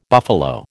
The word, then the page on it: buffalo: American English